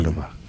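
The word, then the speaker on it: Córdoba: The history lesson we got is it's actually the name of the town in Spain, Cordoba, Spain. Cordoba. So make sure you say it right from now on, Dave.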